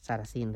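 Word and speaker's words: Sarasin: Belén Ríos es Managing Director para Iberia de Safra Sarasín. Sarasín, lo he dicho bien.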